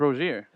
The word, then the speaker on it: Rosier: I've heard it pronounced different. I don't know. – Rozier? – It's Rozier. – Is it Rozier? – That's how I've always said it. That's how I hear him say it.